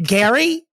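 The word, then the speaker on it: Gerry: Jerry Turner, he, the Golden Bachelor, his wife. Is it Gary? Why do I say Jerry all the time? – But it's like spelled like Jerry, but it's Gary.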